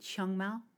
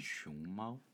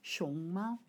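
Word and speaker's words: Xiongmao (熊猫): Not a um, is it Xiangmao? Uh, How do you say panda in Chinese? Xiongmao. Xiongmao.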